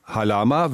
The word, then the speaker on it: Halama: Es heißt genau wie Ihr Name Halama, wird nur im Polnischen vorletzte Silbe betont.